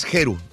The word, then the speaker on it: Geru: yo estaba un poquito confundido, yo pensé que era Jeru porque se escribe con, con, G.